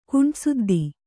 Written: ಕುಂಟುಸುದ್ದಿ

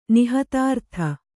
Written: ನಿಹತಾರ್ಥ

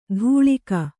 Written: ಧೂಳಿಕ

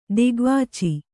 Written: ದಿಗ್ವಾಚಿ

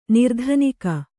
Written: ನಿರ್ಧನಿಕ

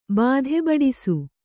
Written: ಬಾಧೆಬಡಿಸು

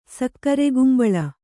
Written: ಸಕ್ಕರೆಕುಂಬಳ

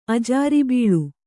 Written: ಅಜಾರಿಬೀಳು

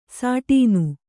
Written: ಸಟ್ಟನೆ

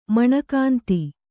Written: ಮಣಲಕಾಂತಿ

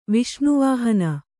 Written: ವಸನವಿಹೀನ

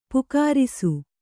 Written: ಪಕ್ಕರಿಸು

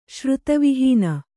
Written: ಶ್ರುತವಿಹೀನ

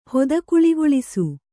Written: ಹೊದಕುಳಿಗೊಳಿಸು

